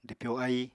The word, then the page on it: dwpyo aíi: apatani